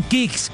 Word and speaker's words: Kixx: Así como los campeones se preparan para ganar, protege tu moto con Kicks, K-I-X-X. Aceites sintéticos y semisintéticos para un máximo rendimiento. Síguenos ya mismo en Instagram como @aceite_kicks. El Kicks se escribe K-I-X-X.